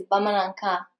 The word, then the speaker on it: Bamanankan: Yeah, it's actually in Bamara. It's Bamanankan, Okay.